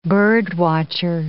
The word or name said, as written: bird-watcher